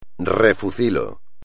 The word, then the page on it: refucilo: espagnol